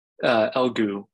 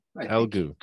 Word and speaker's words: Elegoo: And you picked up a few, how do I say the name properly? Elegoo? Elegoo Jupiters? – Elegoo. – Elegoo. – Just Elegoo, yeah.